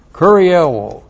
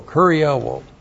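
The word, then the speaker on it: kurieuō: Kurioō, K-U-R-I-E-U, long O, kurioō.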